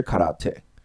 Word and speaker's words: 空手: 他说他们改成的叫空手，就日语读音也是 karate。